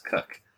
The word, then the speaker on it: Koch: I'm gonna guess that K-O-C-H is pronounced Cook, but Matt I was Cook.